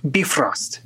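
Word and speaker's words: Bifröst: That one is it would be pronounced a lot closer to beef roast, Bifrost.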